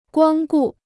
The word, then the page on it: 光顾: guāng gù